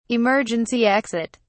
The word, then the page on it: emergency exit: British English